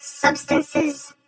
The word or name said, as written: Substances